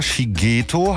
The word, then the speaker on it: Shigeto: Ich sag nochmal seinen Namen klar, Shigeto heißt er.